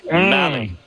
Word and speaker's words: Mahle: you got Muncy at third against Tyler Mahle. – Yeah, rhymes with rally, not Molly, Tyler Mahle.